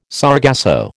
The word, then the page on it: sargasso: American English